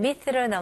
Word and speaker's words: myth: 마지막 TH 발음, 혹시 못하시면 이거는 살짝 메롱으로 생각하시면 돼요. 자, 보세요. myth. 자, 살짝 메롱이라고 설명하는 이유는 이게 제가 발음할 때는 혀가 살짝 보이는 거예요.